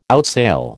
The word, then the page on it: outsail: American English